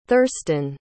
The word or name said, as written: thurstan